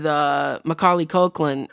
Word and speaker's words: Macaulay Culkin: Have you ever seen The Nutcracker with Macaulay Culkin or whatever his name? What is it? – Yeah, you said it right first.